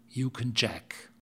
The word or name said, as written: yukon jack